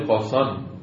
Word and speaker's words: کاشان: علتی هم که بعض کلمات کاف در عربی که میشه قاف میشه، کاشان در عربی قاسان تلفظ میشه، که هایی که در فارسی تلفظ میکنن عربا قاف میشنیدن.